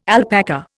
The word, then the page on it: alpaca: Indian